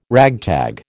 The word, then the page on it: ragtag: American English